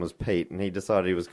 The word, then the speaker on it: Piet: A little while ago, we were calling someone Piet. – Mm. – I found out that it's Pete.